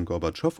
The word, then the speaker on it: Gorbatschow: Gorbatschow, der tauchte damals noch nicht auf, aber da stand dann Gorbatschow und da, wo die Betonung war, Tschow, hatte er sich so einen kleinen Strich reingemacht.